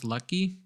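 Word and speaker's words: Luetge: They, they they DFA'd Lucas, I think it's pronounced Lucky.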